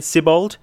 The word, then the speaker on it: Sibbald: So Alex, right, get back to me and tell me if Sibbald is the correct, let's say it again, Sibbald. – How did you pronounce it last week? – Siblad, I think. I think I swapped the L and the A around.